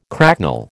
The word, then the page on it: cracknel: American English